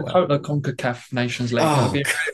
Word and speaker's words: CONCACAF: Is CONCACAF.